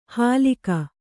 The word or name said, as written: ಹಲಿಕ